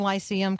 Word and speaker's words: Lyceum: Am I saying Lyceum correctly? – Yeah, that's right.